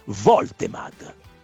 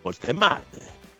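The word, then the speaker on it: Woltemade: È tedesco. Si pronuncia come è scritto. La W iniziale si legge come una V. Quindi Voltemad. Voltemad.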